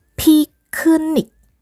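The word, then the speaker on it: piknik (피크닉): piknik piknik pakai piep yang ada bunyi haknya pi lalu ke ya ada KH di situ bunyi h juga keluar diikuti dengan nik piknik piknik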